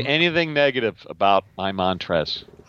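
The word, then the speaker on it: Montrezl: By the way, the L in Montrez is silent.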